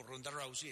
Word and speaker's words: Ronda Rousey: La opinión sobre el publicitado combate entre Ronda Rousey, ¿se pronuncia? – Ronda Rousey, sí.